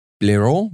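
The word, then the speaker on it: Blériot: I'm probably even saying the name incorrectly. Birot? I think it's Birot, right? Would that be because it's French? – There's a L in there that you haven't pronounced once.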